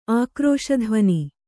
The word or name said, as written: ಆಕ್ರೋಶಧ್ವನಿ